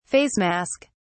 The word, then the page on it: face mask: British English